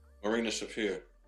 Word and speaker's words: Marina Shafir: Marina Shafir. – Marina Shafir. Yep, you said it right.